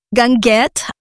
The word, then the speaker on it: guinguette: A guinguette, which, you know, because I got there the last weekend of April, it was an open air.